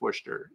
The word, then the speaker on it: Worcester: It's spelled Worcester, but I think it's Worcester is the way you say it.